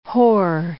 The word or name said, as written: hoar